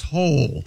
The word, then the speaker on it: Hole: Yeah, Ole. – But it's pronounced Hole. – Yeah. – Hole? – Detective Hole. Yeah. – It's spelled H-O-L-E, Detective Hole.